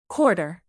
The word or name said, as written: quarter